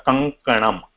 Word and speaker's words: कङ्कणम्: So, Mahavaya, it is K-A-N-G or K-A-G? – Kankatam, because the K is pronounced, and this is the same thing, at the same place where the tongue is being pronounced, we are not changing that position, because, yeah. So, it is coming out Kankanam, Kankanam.